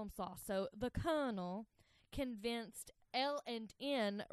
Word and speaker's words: Sloss: And one of the guys that founded Birmingham, Colonel James Withers Sloss. – That felt like the correct way to say that.